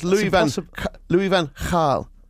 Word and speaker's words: Louis van Gaal: But the BBC themselves, I've got an article on the website which says it's actually pronounced, just to prove we're both wrong, like a KH, like in lock in Scotland. So it's Louis Van Gaal.